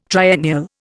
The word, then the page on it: triennial: Indian